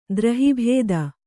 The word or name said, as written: ದ್ರಹಿಭೇದ